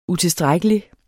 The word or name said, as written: utilstrækkelig